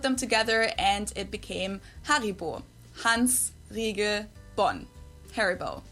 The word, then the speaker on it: Haribo: We pronounce it Haribo. Haribo.